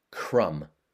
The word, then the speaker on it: crumb: M-U-N-C-H, munch. Another verb, sip. S-I-P, sip. And then a noun, crumb. C-R-U-M-B, crumb. Notice that's a silent B at the end, crumb. And finally, another noun, haven. H-A-V-E-N.